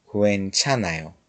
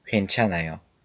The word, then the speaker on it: 괜찮아요: حالا که کوه رو تونستیم قشنگ تلفظ کنیم، بگیم کوئنچانا، کوئنچانایو. یه نکته دیگه هم بهتون بگم، وقتی میخواین کلمه اشکال نداره رو خیلی سریع تلفظ کنید، این کلمه و زیاد شنیده نمیشه ولی حالت لبش گرفته میشه.